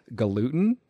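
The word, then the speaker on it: Galuten: The name looked very French to me, but I looked up a video and Noah was on Kelly Clarkson's cooking show and she pronounced it gluten. So Noah Gluten. It kind of looks like galutan to me. That would be the French pronunciation I would go with. But Kelly Clarkson says Noah Gluten, so that's how I'll say it.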